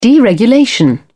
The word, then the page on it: deregulation: British English